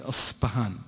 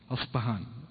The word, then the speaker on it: أصبهان: زين فأصلها هو أورد أن أصلها، وأظن نطقها تكون بالشكل هذا، أن صايرة باء ثلاثية النقط تحت، فأظنها يعني تنطق يعني أصبهان زي البي في اللغة الإنجليزية أصبهان. زين تصير يعني ما هي باء.